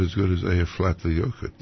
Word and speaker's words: Eyjafjallajökull: Eyjafjallajökull. – Let me hear that. – Eyjafjallajökull. – Eyjafjallajökull. – Eyja means island. Fjalla is mountain. And Jökull is glacier. Eyjafjallajökull.